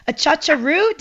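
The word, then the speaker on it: achachairú: A chacharu? Did I get that? – Oh, you nailed it. – Did I get it right? A chacharu? – The most difficult part. Yes.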